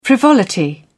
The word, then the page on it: frivolity: British English